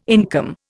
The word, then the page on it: income: Indian